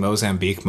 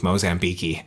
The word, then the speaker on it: Mozambique: And he kept pronouncing Mozambique, Mozambique-y.